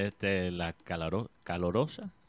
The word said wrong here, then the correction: calurosa: se, dice, lo dije bien. – Calurosa. Sí. Calurosa, Barranquilla.